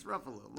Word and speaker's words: Ruffalo: No, it's Ruffalo. Mark Ruffalo. – The Hulk. It was the Hulk. He's in the movie. – No, Mark Ruffalo.